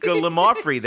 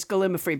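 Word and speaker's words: gallimaufry: I think you might have mispronounced gallimafree there again. – Oh, I did. You're right. Gallimafree. My mistake.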